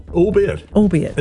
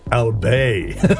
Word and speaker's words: albeit: Albeit. And thought it was pronounced French style, Albay. That's so good. I first said it correctly in my late 20s when Kim Hill said it.